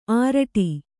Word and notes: ಆರಡಿ: āraḍi

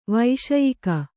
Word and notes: ವೈಷಯಿಕ: vaiṣayika

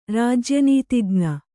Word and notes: ರಾಜ್ಯನೀತಿಜ್ಞ: rājya nītijña

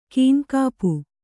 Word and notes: ಕೈನೆಗಪು: kannada

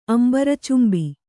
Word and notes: ಅಂಬರಚುಂಬಿ: kannada

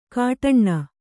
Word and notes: ಕಾಟಣ್ಣ: kāṭaṇṇa

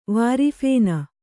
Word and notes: ವಾರಿಫೇನ: vāri phēna

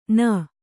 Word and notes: ಣ: ṇa

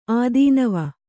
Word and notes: ಆದೀನವ: Kannada

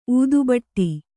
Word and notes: ಊದುಬಟ್ಟಿ: ūdubaṭṭi

